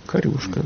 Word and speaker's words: корюшка: Корюшка ударение было, да? – Корюшка, да. – В Ленинграде говорили корюшка.